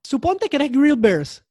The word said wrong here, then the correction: Bear Grylls: Bear Grills, perdón, lo dije mal.